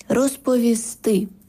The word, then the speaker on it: розповісти: Щодо наголосів, то правильно принести, розповісти, занести. Тобто наголос на останній склад «сти».